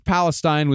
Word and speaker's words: Palestine: Oh, and East Palestine was that happened about 2 or 3 hours north of Point Pleasant. – Yeah, wow. – Palestine. – Palestine, is that how they say it? – That's how they say it.